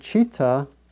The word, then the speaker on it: citta: The word is citta, C-I-T-T-A, and it's sometimes spelled C-H-I-T-T-A, and it's pronounced more like that, citta, C-H.